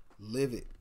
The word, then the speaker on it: LVIHD: name of this artist is Live It you spell that L V I H D. Live it.